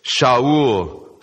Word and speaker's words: Shaul: "So when we had all fallen to the ground, I heard a voice saying to me in the Hebrew language, 'Shaul, Shaul,'" that's how his name is pronounced in Hebrew, "'why are you persecuting me?